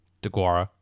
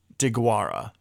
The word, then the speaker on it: Deguara: DeGuara. Josiah DeGuara.